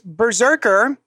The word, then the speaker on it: BRZRKR: So next up, an interesting project, Berserker. It's spelled Brzrkr. I've been pronouncing it Breezewrecker.